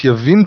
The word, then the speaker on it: Yavin: So Jehovah sold them into the hand of Yabin. Is it Yavin or Yabin? – Well, it's spelt as Yavin. It's pronounced Yavin today.